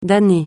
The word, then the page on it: damné: francais